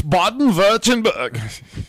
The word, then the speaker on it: Baden-Württemberg: Figure is also preserved in the Pennsylvania Dutch communities and you Brazilian- said that wrong. It's Baden-Württemberg.